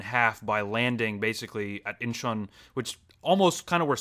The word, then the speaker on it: Incheon: And so then he is off where the Battle of Incheon is underway. Am I pronouncing that correctly? – Yeah, they show the Incheon Landing.